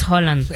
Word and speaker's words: Haaland: Dicen que su pronunciación es Haaland.